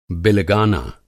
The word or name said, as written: बिलगाना